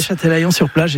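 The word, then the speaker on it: Châtelaillon-Plage: Oui, Châtelaillon-sur-Plage,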